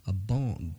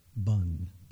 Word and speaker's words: Bon: Well, that's your that last comment reminded me of a bon. It's pronounced, I guess it's pronounced bun, but it's B-O-N. It's most people say a bon, but it's, I think, bun, and it's Tibetan.